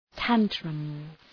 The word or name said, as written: tantrums